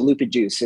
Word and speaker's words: Lupajuice: We pronounce it Lupajuice. It's the, as we say, it is a juicy kind of New England style IPA, and it's the juice of the humulus lupulus, the taxonomy for hops. So it doesn't really matter how you pronounce it, but we get a lot of people that come into the brewery and ask for lupus juice.